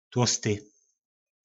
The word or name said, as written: tlosty